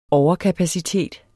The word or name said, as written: overkapacitet